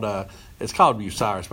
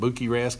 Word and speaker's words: Bucyrus: It's called Bucyrus, but I call it Buki Rest because that's how it's spelled.